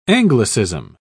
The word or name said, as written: anglicism